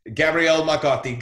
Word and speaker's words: Gabriele Marcotti: Gabriele Marcotti. Did I say that right? – You said it right.